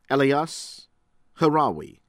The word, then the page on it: ABUNADER, FUAD: foo- AHD AH -boo NAH -dehr; Lebanon